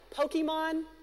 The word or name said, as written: Pokémon